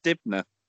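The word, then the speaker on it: Dibnah: I'm pretty sure it's pronounced Dibnor. – Is it Dibnor? Not Dibnor.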